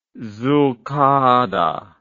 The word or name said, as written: zulkadah